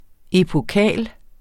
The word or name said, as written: EPOKAL